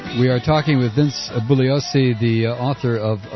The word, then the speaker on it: Bugliosi: Yes, G before L is silent. – Is Vince Bugliosi? – You did a good job.